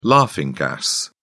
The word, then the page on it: laughing gas: British English